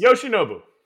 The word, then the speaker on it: Yoshinobu: It's not that Yoshinobu? – Yes. – Yoshinobu.